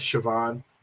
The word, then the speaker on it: Siobhan: Like when they'd be like, oh, that's Siobhan. But it's spelled like S-I-O-B-H-A-N. Like an Irish or something like that. I was like, stop. That's Siobhan or something.